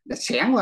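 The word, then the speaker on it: xẻng (想): còn tiếng tàu đọc là xẻng có nghĩa là sự coi như là tri giác, chứ không có cái tưởng tượng trong tiếng Việt mình.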